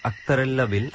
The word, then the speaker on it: আক্তারাল্লা বিল: শুনুন একটা কথা, এটা কি আসলে কি আক্তারাল্লা উচ্চারণ হবে নাকি আক্তার উল্লাহ হবে? এটা কি লোকাল? – আসলে নামটা নিয়ে একটু কনফিউশন আছে অনেকেরই। আমরা যাদের সাথে কথা বলেছি, গ্রামের যারা আছে অনেকেই বলেছে আক্তারাল্লা বিল, আক্তারাল্লা বিল।